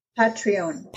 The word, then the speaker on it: Patreon: And also I noticed that you're on Patreon, or how do you say it, Patreon? – Patreon. – Patreon.